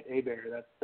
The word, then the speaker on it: Hebert: So you got it right, Hebert. That's the, it's just Hebert, Louisiana.